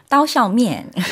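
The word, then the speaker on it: 따오샤오면 (刀削面): 따오샤오면.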